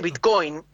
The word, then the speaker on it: Bitcoin: O sea, nadie de toda la comunidad cripto le dice Bitcoin con acento en la O al Bitcoin.